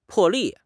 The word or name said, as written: 破例